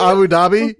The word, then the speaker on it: Abu Dhabi: Abu Dhabi? Like, I was Abu like, Dhabi? – It's, it's pronounced Abu Dhabi, bro."